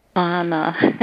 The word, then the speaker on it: Ana: Anna. – Anna, would like kind of like that frozen pronunciation, Anna. – Anna, A-N-A. – A-N-A.